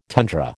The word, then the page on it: tundra: American English